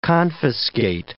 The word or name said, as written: confiscate